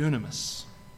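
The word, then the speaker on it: dynamis: Dunamis, right? It's spelled D-Y-N. Doesn't sound like dune. A-M-I-S.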